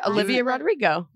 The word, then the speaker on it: Olivia Rodrigo: So you got it, Olivia Rodrigo.